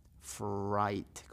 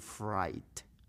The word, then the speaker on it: fright: Fright, como diciendo French fries, papas fritas, fries, pero va a decir fright.